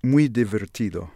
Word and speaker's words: Muy Divertido: This is The Hills of New Jersey from Mark Rebo's album Muy Divertido. Did I say that right? – Muy Divertido.